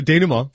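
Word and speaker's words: dénouement: The payoff comes with the film's bittersweet denouement. Did I say that right? The closing action. – Yeah, denouement.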